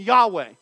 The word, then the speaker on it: Yahweh: We typically pronounce that name Yahweh.